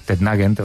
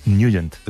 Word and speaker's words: Nugent: Siempre lo hemos llamado en el barrio Tettnagend o Tettnagend. – Claro, ¿no? Yo era de Tettnagend. – Pero la pronunciación correcta es Nugent.